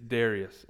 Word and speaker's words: Darius: Now, I think his name is actually pronounced Darius. That sounds a little weird, so I'm going to say it's Darius, all right? Like the lead singer of Hootie and the Blowfish, okay? Like Darius Rucker is the king of Babylon.